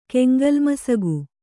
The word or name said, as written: ಕೆಂಗೋಲ್ಮಸಗು